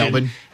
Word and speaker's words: Melbourne: The game is Friday morning in Melbourne time, which, by the way, Melbourne is the preferred pronunciation. Melbourne.